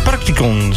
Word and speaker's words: Practicon: Goed, de studio die is volgelopen met jongeren van, uit mijn hoofd, het Practicon. Zeg ik dat goed? – Ja.